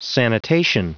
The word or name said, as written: sanitation